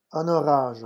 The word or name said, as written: un orage